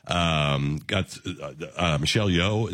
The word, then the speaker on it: Michelle Yeoh: Michelle Yeoh.